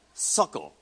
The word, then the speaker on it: sukkah: It's sukkah. It's sukkot. It's actually plural with a T on the end, but the T is silent.